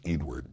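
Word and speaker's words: Eadweard: No, when we were talking with Nikki, I very badly told the story of the person everyone calls Edward Muybridge, though often it's pronounced Edward because of the weird spelling of the first name.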